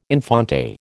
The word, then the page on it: infante: American English